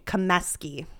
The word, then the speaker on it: Comeskey: When you add the inski, that's when it's so you're Comiskey. – Comeskey. – There's Comeskey, Kaminsky, and Comiskey.